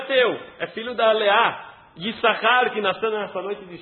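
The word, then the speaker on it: Yissachar (יששכר): Yissachar, embora nós falamos Yissachar, se escreve Yissachar. Yud, Shin, Shin, Chafres. A gente lê só Yissachar, mas tem dois Shin aqui.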